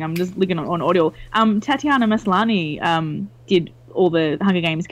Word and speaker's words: Tatiana Maslany: Tatiana Maslany, that's her name.